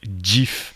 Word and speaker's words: GIF: Dans une interview au New York Times, il avait indiqué que ça se prononce GIF.